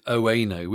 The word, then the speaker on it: Oeno: So yeah, this is a sponsored episode with Oweno, which is spelt O-E-N-O, just to be clear.